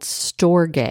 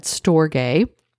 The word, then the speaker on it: storge: The fifth kind of love is called storge, and it's actually spelled like storge, S-T-O-R-G-E.